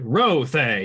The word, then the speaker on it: Rothe: He actually pronounces it Rothe. He puts a lot of emphasis on that first syllable.